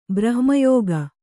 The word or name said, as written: ಬ್ರಹ್ಮಯೋಗ